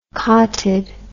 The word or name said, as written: cottid